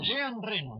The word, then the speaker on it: Reno: Claro, Jean Reno, ¿viste? Reno, igual que el ciervo.